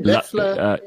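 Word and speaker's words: Loeffler: Luffler.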